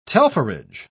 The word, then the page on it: telpherage: British English